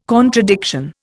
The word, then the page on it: contradiction: Indian